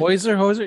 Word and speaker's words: Hozier: And fun fact, do you know the performer, Hozier? I believe that's how you pronounce it. – Hozier? Yeah, I know him, yeah.